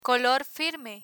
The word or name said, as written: color firme